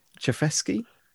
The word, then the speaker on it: Chayefsky: It all kind of starts with this writer, his name I find difficult to pronounce, but it's Paddy Chayefsky.